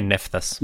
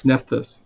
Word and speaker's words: Nephthys: How would you pronounce the sister's name? Nephthys? – I say Nephthys. – Nephthys, yeah.